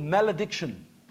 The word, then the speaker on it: malediction: word called malediction. Okay? That's how you'd pronounce it in English.